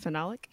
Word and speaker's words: phenolic: How do you say it? Phenolic? – Phenolic, yeah.